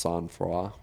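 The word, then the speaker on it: sang-froid: It's spelled Saint Freud, but it's pronounced Sanfroid.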